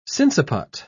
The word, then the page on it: sinciput: British English